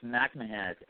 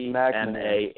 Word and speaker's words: MacMahan: And just quickly, I think I've been pronouncing your last name wrong. I apologize. It's Justin MacMahan. – Yeah, it's MacMahan. MacMahan.